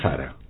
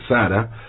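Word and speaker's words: Tzara: que era Tristán Sara. Tristán Sara, se escribe Sara con Z y una T adelante, Sara, nació en Rumania, en una familia de origen judío, de una familia judía.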